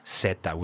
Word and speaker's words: zeta: Zeta, with an S sound.